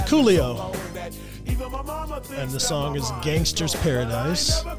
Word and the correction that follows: Gangsta's Paradise: Gangsta's Paradise. There's you. – Not, you gotta say it right.